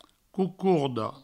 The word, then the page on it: cocorda: coucourdo